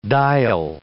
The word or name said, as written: diel